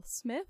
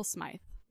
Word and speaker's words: Smythe: I had pronounced it Ethel Smith, and one of our listeners said, Hey, not quite right. And so I'm so glad that she let us know because, goodness gracious, these English names. But Ethel Smythe is the way that you pronounced our dear dame,